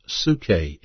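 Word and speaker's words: psuche (ψυχή): A body, pronounced soma in the Greek, a spirit, pronounced pneuma in the Greek, and a soul, pronounced psuche in the Greek.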